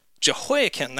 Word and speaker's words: Jehoiachin: Is that how you say it? Jehoiachin, that's right. So he was taken into captivity by Babylon.